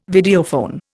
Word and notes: Videophone: Indian

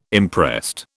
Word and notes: imprest: British English

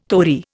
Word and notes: tori: Indian

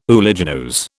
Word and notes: uliginose: British English

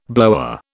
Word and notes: blower: British English

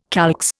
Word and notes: calx: Indian

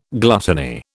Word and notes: gluttony: British English

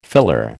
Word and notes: filler: American English

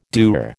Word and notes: dour: American English

